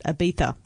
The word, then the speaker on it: Ibiza: I saw Netflix had a new movie up called Ibiza, or as you're supposed to pronounce it, Ibiza.